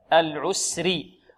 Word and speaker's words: العسر: هذه قمرية لأنها ظهرت، القمرية العسري. الراء هنا ماذا؟ مفخمة ومرققة. لماذا رققناها؟ لأنها مكسورة. بارك الله فيكم.